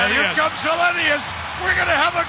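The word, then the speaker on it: Helenius: Can't really pronounce Hellenius. – This guy's Hellenius.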